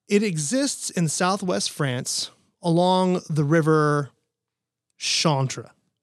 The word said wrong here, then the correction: Charente: Charente. – See, I'm so glad Tim's here because I'm not pronouncing this stuff correctly.